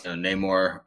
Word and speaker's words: Namor: I know that you're excited about Namor. Namor, how am I saying that wrong? – Yeah, no, I think you're getting it right on. It's Namor,